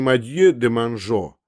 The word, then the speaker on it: Мадье де Манжо: Вдруг маленький поляк произнес имя депутата Мадье де Манжо, но по привычке очень многих поляков выговорил его по-польски, то есть с ударением на предпоследнем слоге, и вышло не Мадье де Манжо, а Мадье де Монжо.